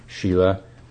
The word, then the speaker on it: sila: So that's how the morality, so-called Shila, the Sanskrit word is S-I-L-A, pronounced Shila.